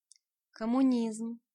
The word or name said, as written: Communism